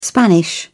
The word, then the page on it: spanish: British English